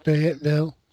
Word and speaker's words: Fayetteville: It's like Lafayette, but without the law. So I guess it's Fayette. – I'd say Fayette, yeah. – Fayetteville? – Fayetteville.